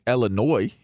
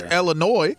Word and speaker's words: Illinois: If Mark Davis was saying Illinois, he'd be good. I mean, I'm just saying, you know, if it was Josh Illinois, everything would have worked out perfectly fine. – The silent S.